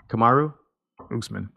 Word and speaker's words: Kamaru Usman: Kamaru? – Usman.